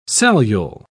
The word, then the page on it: cellule: British English